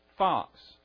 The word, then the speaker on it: Fuchs: And this is by Joanna Fox. And I'm pronouncing that way because there's a road right by my house and they pronounce it Fox Road. It's spelled F-U-C-H-S.